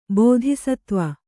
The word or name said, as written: ಬೋಧಿಸತ್ವ